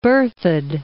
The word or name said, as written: Berthoud Pass